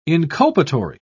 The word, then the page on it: inculpatory: British English